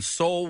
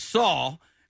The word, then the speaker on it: Soul: Tracy Morgan pronounced it as Saul, right? He goes, and the winner is, and he goes, Saul. And then there was no reaction because there was no movie called Saul.